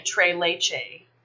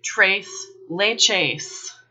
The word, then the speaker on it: Tres Leches: This contingent goes around calling it Tres Leches. In reality, the cheese is Spanish, so it's pronounced Tres Leches. The S's are pronounced Tres Leches.